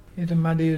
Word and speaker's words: Irrumardi: Irumadi is brim, the fish brim, and it's spelled IRRUMARDI.